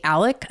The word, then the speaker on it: Alex: So Alec Murdoch, which makes no sense. His name is spelled Alex, but they say Alec.